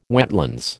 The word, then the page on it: wetlands: American English